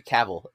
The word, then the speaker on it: Cavill: Like Henry Cavill is a huge, or sorry, Cavill is how you say his name.